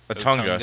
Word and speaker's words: Otunga: Truth has David Antonaga, or wait, Otunga. Sorry, I don't know why I always add an extra syllable for some reason with some of these names.